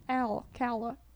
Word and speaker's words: Alcala: O-C-O. – Alcala.